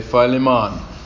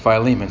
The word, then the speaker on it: Philemon: Moving on here in this little letter called Philemon, people pronounce it differently. The Jamaicans say Philemon, but I just say Philemon.